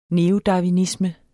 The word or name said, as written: neodarwinisme